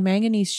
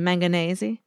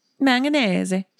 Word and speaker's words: manganese: So if you had manganese shavings, am I saying that right? – You are. – I've never said it before. – Do you want it to be manganese-y? – Manganese. Yeah. – Sounds delicious, but no, it's manganese.